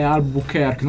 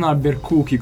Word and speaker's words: Albuquerque: Ecco, io vorrei che tu dicessi che la pronuncia corretta è Albuquerque, non Albuquerque, come dice qualcuno in Italia. Albuquerque. Albuquerque, se andiamo a vedere,